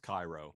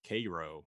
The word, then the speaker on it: Cairo: Take, for example, the word spelled C-A-I-R-O. In Egypt, it's pronounced Cairo. In South Georgia, it's pronounced Cairo.